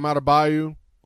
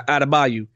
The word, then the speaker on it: Adebayo: Last night, this being Wednesday, so it was Tuesday night, we had the big block from, was it Abaju? How do you say his name? The dude from the Heat. – Bam Adebayo. – Yeah, Adebayo.